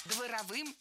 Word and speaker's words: дворовым: Дворовым языком – это неверно, правильно, дворовым. В прилагательном, образованном от существительного «двор», ударение ставим на второй слог – дворовый, дворовая, дворовыми.